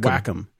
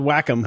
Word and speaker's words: Wacom: When I go to SIGGRAPH, I'd go to the booth and they would always chastise me and say it's called Wacom.